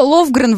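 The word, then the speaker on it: Lofgren: Зо Ловгрен, Валерий, пишется через F, именно поэтому вы не можете найти ее, вы пытаетесь загуглить.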